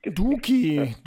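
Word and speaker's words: Doekhi: Türki, Danilo Türki. – Duki, Duki spricht man aus.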